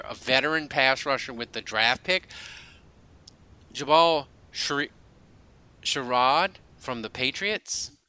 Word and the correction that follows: Sheard: Is that Sheard? Is that how you say it? – Yeah. – So Jabal Sheard.